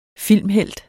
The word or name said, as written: filmhelt